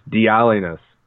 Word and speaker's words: Dialynas: Just like it was so funny when he was just like, "Stop putting an inflection on stuff. It's just Dialynas."